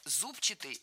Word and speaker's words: зубчатый: Мы услышали прилагательное «зубчатый» с ударением на первом слоге. И это правильный вариант. Но правильным он стал совсем недавно. Раньше нужно было ударять второй слог «зубчатый».